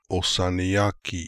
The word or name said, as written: Ossaniaki